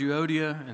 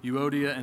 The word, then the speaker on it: Euodia: Now, that's how I researched linguistically how to say their names. If you want to say it some other way, have at it. I'm fine with it. Rock on. But I'm going to go with Euodia and Syntyche.